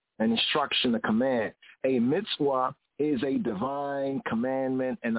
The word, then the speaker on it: mitzvah: The Hebrew word for commandment is mitzvah, according to the Afro-Semitic, Afro-Asiatic pronunciation. Mitzvah. Modern Hebrew will be said as mitzvah.